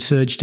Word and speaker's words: SRJ: Moving from the A-Leagues to, is it Surge? Is that the correct pronunciation? – It is, yeah, Surge Sports Investments.